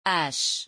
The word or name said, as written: hache